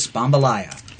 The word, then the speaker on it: Spambalaya: Anyway, she won it with her beer-driven entry of Spambalaya. – Spambalaya. – Spambalaya. – And that is the correct pronunciation of Spam. – It is. Spambalaya. – Spambalaya. To make it rhyme with jambalaya, as opposed to Spambalaya.